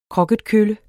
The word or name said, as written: kroketkølle